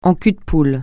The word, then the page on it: en cul-de-poule: francais